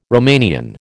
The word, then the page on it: Romanian: American English